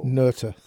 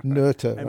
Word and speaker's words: Noether: Noether. Noether, right? – Noether. – Noether. – Yeah. Emily Noether. – Yeah. – Fascinating person. – But if you'd like to say Noether, that's fine.